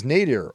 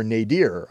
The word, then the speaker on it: nadir: That word is nadir, or nadir. I think either pronunciation is correct.